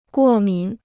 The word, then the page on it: 过敏: guò mǐn